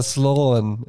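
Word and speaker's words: Siobhán: Äh, Slopnan Donaghy. – Ach, Sloan oder was? – Ja, Sloan. Ja, aber es wird äh, ganz anders geschrieren.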